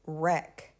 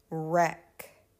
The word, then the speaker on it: wreck: However, the W is silent and we pronounce the R. Here are some examples. Wrap, wrap, wrap. And this is if you're going to gift wrap a present. Wrap. wreck, wreck, wreck.